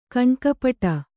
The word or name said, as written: ಕಣ್ಣುಕಪ್ಪಡಿ